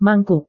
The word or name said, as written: mangkuk